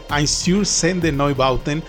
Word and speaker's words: Einstürzende Neubauten: Me refiero a los alemanes, regresamos a Alemania, a los alemanes Einstürzen de Neubauten, quienes muchas veces lo he pronunciado de muchas formas, pero al parecer esa es, creo que ya la traducción, bueno, más bien la pronunciación correcta, Einstürzen de Neubauten.